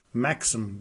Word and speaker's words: Maxim: Maxim Gorky.